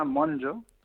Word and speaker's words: Monlló: Sí, el apellido es catalán, entonces se pronuncia Jota Monlló, como Monlló. Si fuese en inglés.